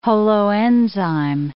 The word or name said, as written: holoenzyme